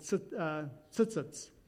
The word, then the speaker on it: tzitzit: Well, this is what in Jewish times today, these are called tzitzits. How do I say it? Okay. Well, we can argue about how to pronounce it, but it's T-Z-I-T-Z-I-T. Tzitzit.